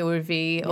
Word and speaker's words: Urvi: So do your parents, surely your parents don't call you Irvi? – No. They call me Urvi, or in India you have, like, annoying nicknames for people.